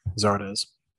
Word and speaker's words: Zardes: And then there was uh Zard Zardis Zardis. How do you say that? Zardes. Zardes. I said it wrong earlier.